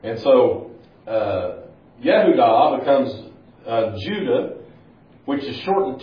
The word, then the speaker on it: Yehudah: So Jacob, as we said, is going to have a son, and his name is going to be Judah, or Yehudah is how it's pronounced in Hebrew. Yehudah.